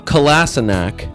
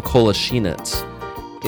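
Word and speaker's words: Kolašinac: Would it be Kolasinac? – No. His name is Sayad Kolasinac. – What? – Whoa! – The correct pronunciation is Kolasinac.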